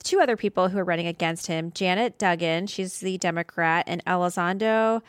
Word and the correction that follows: Dudding: Oh, I think I said her name wrong. I'm sorry. Dudding. Janet Dudding. I think I said Dugging.